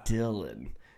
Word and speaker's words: Dillian: I like that, and Dillian Harper. – Oh, dude. – Yeah. – You know that it's actually pronounced Dylan, but yes, it's spelled Dillian.